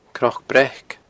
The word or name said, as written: cnocbreac